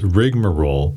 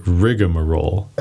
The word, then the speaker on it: rigmarole: What's funny about this word is that although its dictionary pronunciation is rigmarole, people often throw in an additional A in there and they say rigmarole.